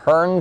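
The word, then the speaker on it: Hearne: Hearn, Texas. Am I saying that right, Carolyn, watching Free Speech TV? – Yes, you are.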